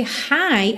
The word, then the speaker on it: high: Lembra que esse high, H-I-G-H, tem exatamente a mesma pronúncia do high. high, hello, somente H-I, ok?